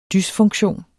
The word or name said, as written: dysfunktion